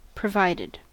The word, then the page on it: provided: American English